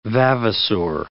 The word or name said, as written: vavasor